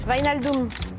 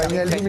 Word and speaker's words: Wijnaldum: Wijnaldum. – Ah ouais. – Wijnaldum, il est arrivé libre.